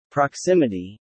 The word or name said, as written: proximity